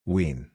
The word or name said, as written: wean